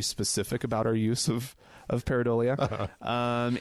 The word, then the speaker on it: pareidolia: Thank you, Wendy, for correcting my pronunciation. Pareidolia is, I guess, how you're supposed to say it.